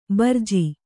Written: ಭರ್ಜಿ

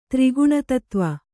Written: ತ್ರಿಗುಣತತ್ವ

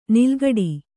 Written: ನಾಲುಕಟ್ಟು

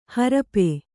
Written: ಹರಪೆ

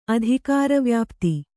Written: ಅಧಿಕಾರವ್ಯಾಪ್ತಿ

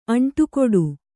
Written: ಅಂಟುಕೊಡು

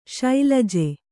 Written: ಶೈಲಜೆ

